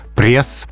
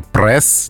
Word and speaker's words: пресс: А, на самом деле все несложно, потому что независимо от значения, пресс произносится именно так, с мягким «ри». А словари отмечают, что произношение «пресс» неправильно.